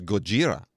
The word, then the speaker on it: Gojira: Yeah, the actual name in Japan is Gojira. – Oh, G-O-J-I-R-A. Yeah. But, you know, Americans being as slovenly as we are, we pronounce it as Godzilla, and there we are.